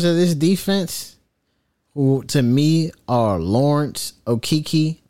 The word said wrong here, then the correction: Okereke: I know that's not how you say his name. Thibodeau. And those two. – You said, are you talking about Okereke? – Okiki, yeah, him.